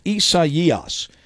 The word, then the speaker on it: Isaias: It is spelled Isaiah, but it is pronounced Isaias.